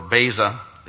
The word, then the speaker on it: Beza: The former view arose from a man named Theodore Beza, it's B-E-Z-A, but pronounced Beza, Calvin's successor at Geneva, who ultimately rejected Calvin's view of assurance.